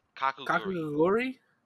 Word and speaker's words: Kakegurui: So wait, how is it pronounced? Kakegurui. Kakegurui? – Yes.